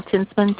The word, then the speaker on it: Tinstman: My last name, Tinsman, T-I-N-S-T-M-A-N.